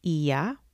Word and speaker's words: Oia: which is a site of Santorini spelled O-I-A, but pronounced Ia.